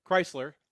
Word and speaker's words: Kreisler: So Kreisler. As in Chrysler Arena. Yeah, we love to confuse people in Michigan because we spell the name Chrysler differently in two different ways and they're pronounced the same way. So if you're driving a Chrysler or you're talking about the man Chrysler, it's the same pronunciation.